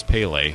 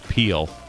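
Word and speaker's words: Pelé: I was a smart aleck, and the kid was reading the Weekly Reader, and there was a story about Pele, and I knew that it was Pele, and this kid pronounced it Peel.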